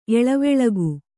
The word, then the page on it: ಏಲಾವಾಲುಕ: elā vāluka